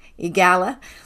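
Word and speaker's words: EAGALA: She's been trained and is certified in EGALA. Did I say that right, Tammy? EGALA? – Yes, you sure did.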